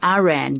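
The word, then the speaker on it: Eran: Because it sounds like Iran, but how do you? E-R? – Yeah. – A-N. – Good. Okay. – And in Hebrew, it's Ein Resh Nun, which is Iran, in fact.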